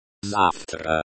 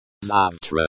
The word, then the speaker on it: Завтра: Завтра. Завтра. The emphasis is on the first syllable.